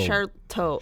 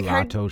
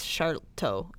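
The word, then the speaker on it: Sharlto: It's like Charlotto, Charlito. – No, it's not that. It's not that many syllables. I think it's just Charlto. It's because it's South African, so I think it's just a little different for us.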